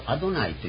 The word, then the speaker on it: アドナイ: であまりの恐れとは読み方は別のですね、主というアドナイというのこと。